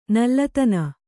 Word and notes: ನಲ್ಲತನ: nallatana

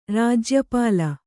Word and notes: ರಾಜ್ಯಪಾಲ: rājya pāla

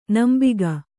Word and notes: ನಂಬಿಗ: nambiga

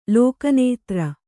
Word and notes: ಲೋಕನೇತ್ರ: Kannada

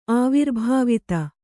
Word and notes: ಆವಿರ್ಭಾವಿತ: Kannada

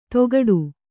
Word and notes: ತೊಗಡು: Kannada; togaḍu

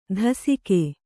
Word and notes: ದಾಸಿಕೆ: dāsike

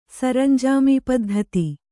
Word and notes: ಸರಂಜಾಮೀಪದ್ಧತಿ: Kannada

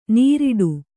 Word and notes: ನೀರಿಡು: Kannada

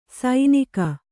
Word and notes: ಸೈನಿಕ: sainika

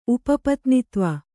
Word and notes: ಉಪಪತ್ನಿತ್ವ: Kannada

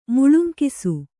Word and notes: ಮುಳುಂಕಿಸು: kannada

